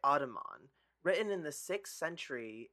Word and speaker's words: Audubon: All right, so writing, according to Audubon, I feel like I'm saying Ottoman, but I Ottoman. Know this is how you say it. – Audubon.